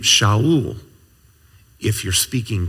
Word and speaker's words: Shaul: And so I can just imagine the parents of the Apostle Paul, 1,000 years later, and name him Saul, which in Hebrew you would pronounce Shaul.